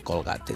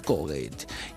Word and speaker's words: Colgate: Sí, y entonces dice, no se dice Colgate, se dice Colgate.